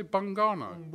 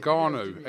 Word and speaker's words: Ngannou: Did you say Bungano? – Bungo, whatever his name is, I don't know. – Ngannou.